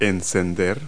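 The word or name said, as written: limp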